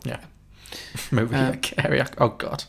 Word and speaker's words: Mariah: Did you just pronounce her Maria Carey? – I did, yes. – Oh, come on. – I was... very 50-50 on it. I didn't know. – It's Mariah Carey.